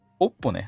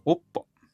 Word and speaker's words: OPPO: おっぽね、おっぽ。